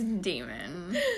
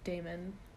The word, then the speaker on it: Daemon: It's Demon. Oh, you guys, I read these books probably almost a decade ago, and the character's name is spelled D-A-E-M-O-N, and I was like, Damon. Then I listened to the audiobooks, and they were like, Demon, and I refused to believe it.